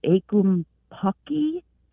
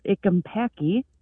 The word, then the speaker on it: ackumpucky: And as I was trying to find this word and find something, I came across a word, I think you would pronounce it ikumpaki. And it is a plumbing term used for the gunk caught in the threads when you're taking pipes apart and whatnot. – Oh, wow. – Oh, really? – And I thought, that's amazing. You did astonishing work there. How do you spell that? – I think it was spelled ACKUMPUCKY. And so I found that quite interesting. And it matches up with his ikumpaki.